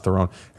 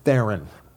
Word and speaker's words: Theron: It's not Theron. It rhymes with Darren. That's how I remember now. Oh. Charlize Theron.